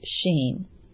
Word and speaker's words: Shin: So now we'll move on to the 21st letter of the Hebrew alphabet, which is Shin, S-C-H-I-N, but it's pronounced Shin.